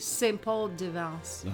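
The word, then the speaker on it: Saint-Paul-de-Vence: He died on December 1st, 1987, from a stomach cancer in St. Paul de Venise, France. Is that how you say it? Venise? – St. Paul de Vence. – Oh, St. Paul de Vence, France.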